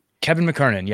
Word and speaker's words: McKernan: Kevin McKernan, yes.